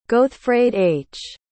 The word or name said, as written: gothfraidh